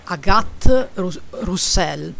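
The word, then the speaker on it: Agathe Rousselle: sì, allora si chiama Agathe Rus- Roussel, detto okay. con la R italiana.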